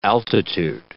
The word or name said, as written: altitude